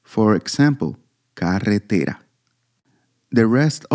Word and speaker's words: carretera: In the word carretera, for example, the stressed, the strongest intensity with which we pronounce a syllable within a word, falls on the penultimate syllable, te.